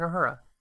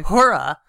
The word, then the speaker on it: Uhura: I think it's spelled U-H-U-R-A. – Yeah, Lieutenant Uhura. – Oh, okay. You're just sort of dropping that. I know, which is why you're calling her Hora.